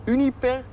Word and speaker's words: Uniper: We staan hier bij de Uniper Centrale, zeg ik dat goed? – Ja, zeg je goed, ja.